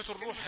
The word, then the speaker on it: الروح: كثيرون يخطئون في نطق كلمة الروح، فبدلا أن يقول الروح يقول الروح الروح، جعلها ضمة يعني مش رائبة إلى الفتح الروح. يقولوا ويسألونك عن الروح، نقول له لا، عن الروح، عن الروح، الضمة معناها ضم الفم، فأنت تقول الروح يعني جايب الضمة يعني مش رائبة إلى الفتح، مختلطة بفتح الروح.